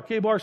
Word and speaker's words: Chebar: He came by the brook called Chebar or Kabar, some pronounce it that way as well.